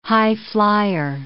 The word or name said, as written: highflier